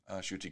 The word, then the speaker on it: Ncuti: Apparently it's Shuti Gatwa. It's spelled N-C-U-T-I, but it's pronounced Shuti Gatwa. That's how I've heard people saying it.